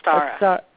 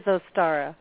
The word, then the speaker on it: Ostara: Is it Ostara or Easter? – No, Ostara. – It is Ostara.